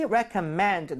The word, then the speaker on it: recommend: So, let me recommend. 내가 추천을 좀 해줄게. 대신에 이제 발음할 때 recommend가 아니라 발음 어떻게 했어요? – recommend. – recommend입니다. 얘는 명사가 돼서 recommendation이 돼도 여전히 re로 발음하셔야 돼요.